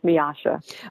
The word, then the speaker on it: Miosha: Like I said, I answer to anything, but it's actually pronounced Miosha.